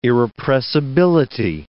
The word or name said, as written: irrepressibility